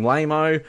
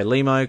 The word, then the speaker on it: Limo: I fucked up the pronunciation of the Limo guy. I called him Lamo. And then, so that's why in the recording, like, I point to the camera when I say Limo, because I got it right that time.